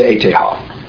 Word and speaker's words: ETH: And in German they say the E like an A, like a long A. T like a T, H like a Ha. So it's called the ETH Ha.